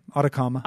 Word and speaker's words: Atacama: Yeah, Atacama.